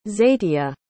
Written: zeitia

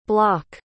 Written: bloch

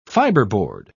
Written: fiberboard

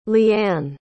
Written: leeanne

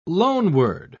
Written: loanword

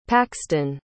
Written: Paxton

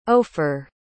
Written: ofer

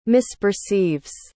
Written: misperceives

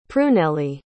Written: prunellie

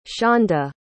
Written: shawnda

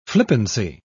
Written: flippancy

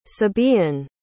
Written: sabaean

